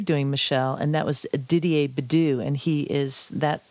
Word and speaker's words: Didier: Sure, it's Didier, and that's spelled D-I-D-I-E-R, Bedu, and that's V-E-D-U.